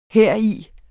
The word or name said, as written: heri